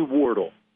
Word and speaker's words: Wardle: It's actually Wardle. – Wardle. Okay. – You were close, but it's Wardle.